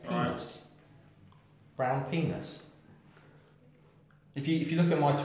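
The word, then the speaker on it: Brown Pinas: Is that how you pronounce it? Or is it Peanut? Is it Pinar? – No, it's Brown Penis, mate. Obviously, P-I-N-A-S.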